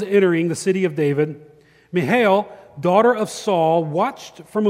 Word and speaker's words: Michal: Is it Michael? Is it, it's Michal. That's how the Hebrews would have pronounced it. The C would have been silent.